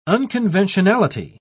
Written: unconventionality

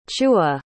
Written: chu'a

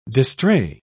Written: distrait

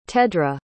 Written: tedra

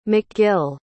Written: mcgill

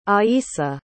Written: aissa

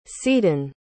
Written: seadon

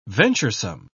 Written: venturesome

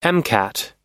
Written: mcat

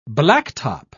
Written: blacktop

